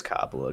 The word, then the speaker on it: Coppola: I think it's Coppola, because that's from the Kanye lyric.